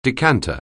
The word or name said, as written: decanter